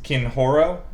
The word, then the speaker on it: Kenhoro: The main capital city of Zadal is Kinhoro. It's spelled K-E-N-H-O-R-O.